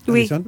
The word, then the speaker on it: Harrison: Ah, là, c'est bien prononcé, Harrison ? – Oui, oui, très bien.